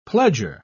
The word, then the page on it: pledger: British English